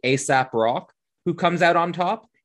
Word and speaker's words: Aesop Rock: ASAP Rock, if you look him up, it's spelled A-E-S-O-P, R-O-C-K. – All right, okay. – Like it's based on ASAP's Fables.